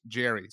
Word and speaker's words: Jere: Nice to meet you, Manny, from Jer, pronounced Jerry. So it's actually written J-E-R-E.